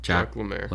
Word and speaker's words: Jacques Lemaire: We have Jacques Lemaire. Did I pronounce that right? – Jacques Lemaire.